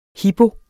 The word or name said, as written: hipo